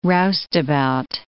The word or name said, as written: roustabout